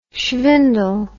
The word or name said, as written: shvindle